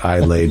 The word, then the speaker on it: Islay: Like uh, Islay I got. I lay down. See how that's how oh, I remember that now. I lay down next to the Loch Lomond.